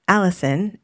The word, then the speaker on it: Allyson: My name, as you know, is Allison. It's spelled a little unusually. My mom gave me an unusual spelling, A-L-L-Y-S-O-N.